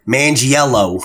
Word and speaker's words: Manganiello: Manganiello. That's how you pronounce it, John.